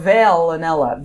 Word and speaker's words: Veillonella: It's violonella, Viol or Veillonella. V-E-I-L-L-O-N-E-L-L-A. – Like Kurt, Like Kurt Vile.